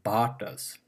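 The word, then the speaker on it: Bhatta: This philosopher was named Kumaralabhata, and the thinkers that follow him get called Bhattas, with also a long A instead of a short one.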